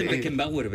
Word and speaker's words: Beckenbauer: Dice Beckenbauer, ben. – Moi ben pronunciado.